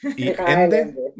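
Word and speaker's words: Michael Ende: Por cierto, Noemí, ¿cómo pronunciamos a Ende? – Mijael, Mijael. – Mijael Ende? Mijael. Ende, perfecto. Eh,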